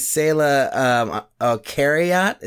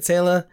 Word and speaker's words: Saila Kariat: So the voice that you're hearing is Saila Kariat. Is that how you say it, Saila? – Yes.